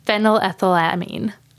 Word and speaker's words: phenylethylamine: And how it's scientifically studied is that there's actually a chemical component linked to it, and it's increased neurotransmitters, specifically phenylethylamine.